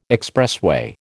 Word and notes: expressway: American English

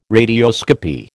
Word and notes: radioscopy: American English

